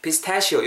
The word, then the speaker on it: pistachio: pistachio 이렇게 발음하셔야 돼요.